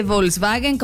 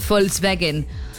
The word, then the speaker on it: Volkswagen: Recordemos que la V se pronuncia como F. Como Volkswagen. No se dice Volkswagen como lo decimos nosotros, sino honestamente debería ser Volkswagen.